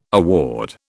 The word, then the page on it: award: British English